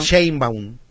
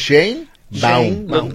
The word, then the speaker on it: Sheinbaum: Sheinbaum. Yo así lo pronuncio, que sería lo más literal. – Sheinbaum. – Sí, también. Pero así lo pronuncio yo, no sé si originalmente Sheinbaum. Así ¿tú sea. ¿Cómo? – Sheinbaum. – Sheinbaum. – Con M al final. – Sheinbaum. Pero marcando que es Sheinbaum.